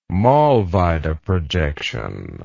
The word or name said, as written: Mollweide projection